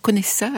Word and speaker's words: Conesa: J'ai entendu, c'est Pierre Conesa à l'italienne.